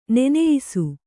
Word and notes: ನೆನೆಯಿಸು: neneyisu

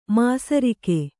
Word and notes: ಮಿಸಿರಿಕೆ: misirike

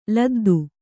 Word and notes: ಲದ್ದಿ: kannada

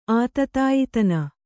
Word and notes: ಆತತಾಯಿತನ: Kannada

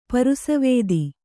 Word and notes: ಪರಸ್ವತ್ತು: para svattu

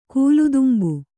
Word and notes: ಕೂಲುದುಂಬು: kūludumbu

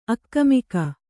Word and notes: ಆಗಾಮಿಕ: kannada

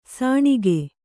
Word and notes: ಸಾಣಿಗ: sāṇiga

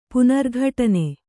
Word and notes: ಪುನರ್ಘಟನೆ: Kannada

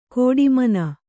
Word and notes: ಕೋಡಿಮನ: kōḍi mana; Kannada

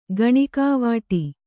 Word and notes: ಕೆಣಕುವಡು: Kannada

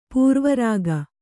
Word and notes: ಪಾರಾವಾರಗ: kannada

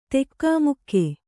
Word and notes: ಧಕ್ಕೆಮುಕ್ಕೆ: dhakke mukke

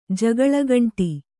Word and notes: ಜಗಳಗಂಟ: Kannada

